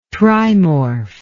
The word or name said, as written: trimorph